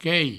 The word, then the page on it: quei: provençal